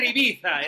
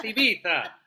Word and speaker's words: Ibiza: If you want to make people roll your R's, you have to, los españoles te van a decir, o sea, gilipollas, tiene que ser Ibiza, es Ibiza.